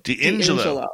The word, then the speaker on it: D'Ingillo: D'Ingillo.